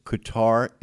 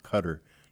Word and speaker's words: Qatar: Oh, by the way, Qatar is the proper pronunciation for that country. It's not Qatar.